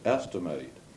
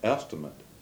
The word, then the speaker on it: estimate: when you're pronouncing the verb form, you say estimate, mm-hmm. – while an estimate is the name.